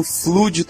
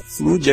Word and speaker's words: Flood: Vocês estavam falando flood o tempo todo sobre a raça lá, e não é Flood, é Flood, assim, bem como o nome da franquia, que não é Halo, é ou Halo ou Halo, que é a pronúncia em português do Brasil ou em inglês.